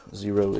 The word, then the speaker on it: Xero: The name Zero is spelled with an X as you can see.